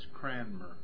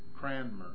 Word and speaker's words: Cranmer: And his name is pronounced? – Thomas Cranmer. – Cranmer. Spell it. See. Let's see. In case you're... – Top of the page. – C-R-A-N-M-E-R.